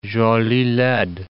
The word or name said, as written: jolie laide